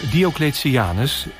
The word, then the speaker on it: Diocletianus: Nou, zal ik je even laten horen hoe het uitgesproken moet worden? – Maar, ja. – Hier komt. – Goed. – De plek waar ik ben is... het paleis van Diocletianus in Split, Kroatië.